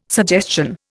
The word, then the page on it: suggestion: Indian